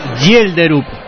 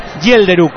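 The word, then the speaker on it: Schjelderup: Bueno, yo diría con la 21, Andreas es el nombre y el apellido es Helderup. – Schelderup. – Schelderup. – Andrea. Schelderup. Para que me pasó el profesor Sima la pronunciación. Dame un segundo. – Gielderup.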